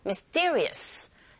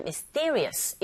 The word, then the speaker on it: mysterious: 이때 미스테리어스처럼 우리식의 말을 하지 마시고 mysterious, 이 miss 뒤에 to, 자, 이 to 사운드가 된소리 현상이 일어나면서 mister보다는 mysterious 이런 식으로 발음하시면 되고요.